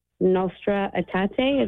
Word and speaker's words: Nostra Aetate: I just got done reading the Nostra Aetate. Is that how you pronounce it? – Yeah, exactly.